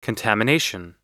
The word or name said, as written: contamination